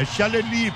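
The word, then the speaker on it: Chaalali: É Chaleli, porque é francês.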